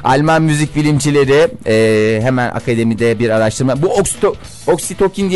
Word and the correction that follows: Oksitosin: diye bir şey var ya. – Oksitosin evet. – Oksitosin mi? Hı. Ha doğru, Almanca olunca S okunuyor. Oksitosin.